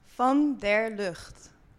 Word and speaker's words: Van der Lugt: Mirte, how do you say your last name again? – Van der Lugt.